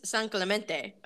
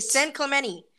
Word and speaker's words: San Clemente: So my right away thing of knowing if somebody knows what the fuck I'm talking about is how they pronounce it. If they say San Clemente, I know they're from San Clemente or they've really been there. But if they say San Clemente, you're like, all right, well.